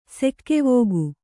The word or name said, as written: ಸೆಕ್ಕೆವೋಗು